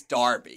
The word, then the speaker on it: Derby: D-E-R-B-Y. Oh, my goodness. – Is Darby. – It's spelled L-E-I-C-E-S-T-E-R, so you can see I can make that mistake.